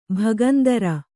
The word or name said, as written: ಭಗಂದರ